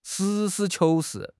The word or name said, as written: 实事求是